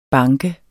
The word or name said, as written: banke